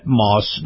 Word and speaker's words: Maus: Moss is spelled M-A-U-S, and in particular, the Moss Nissan dealerships.